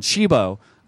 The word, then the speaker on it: Cibo: there's this restaurant in West Hollywood called Cibo, which is really the Italian word for food, C-I-B-O, but most people couldn't pronounce it right. So the guy changed his name of the restaurant. Now it's called Cibo, C-H-E-E-B-O.